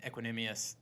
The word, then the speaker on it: Equanimeous: And then he has Courtland Sutton and Equanimeous St. Brown. I think I said that right. – Yeah, you did.